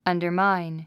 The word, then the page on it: undermine: ʌ̀ndə r máin; アンダァマァイン